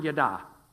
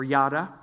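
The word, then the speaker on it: yada (ידע): It is the word yada, or yada.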